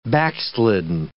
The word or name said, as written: backslidden